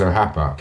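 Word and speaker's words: O Rappa: It's spelled Orapa, R-A-P-P-A. – Clever. – But apparently it's pronounced Ohapa.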